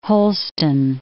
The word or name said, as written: Holston